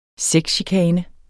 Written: sexchikane

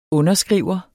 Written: underskriver

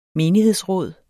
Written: menighedsråd

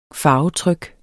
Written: farvetryk

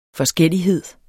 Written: forskellighed

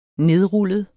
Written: nedrullet

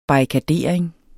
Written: barrikadering

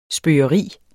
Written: spøge

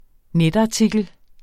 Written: netartikel

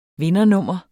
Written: vindernummer

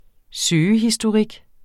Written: søgehistorik

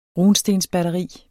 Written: batteri

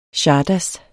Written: czardas

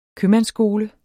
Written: købmandsskole

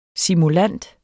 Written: SIMULANTERNES

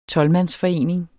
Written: tolvmandsforening

